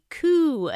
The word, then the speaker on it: coup: Then I spotted the coop in someone's yard. Looked to me like a coop attempt. Oops, sorry, I mispronounced that. I meant a coup attempt.